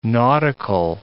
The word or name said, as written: nautical